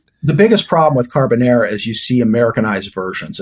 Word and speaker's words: carbonara: Carbonara. I'm sorry, I'm mispronouncing it. Carbonara.